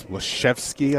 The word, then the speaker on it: Leszewski: They've got a decent big with a, was it Lazewski? But he's Leszewski, I think. Is it how they say it? Yep. – Yeah, it's Polish, so it's a little different. Leszewski.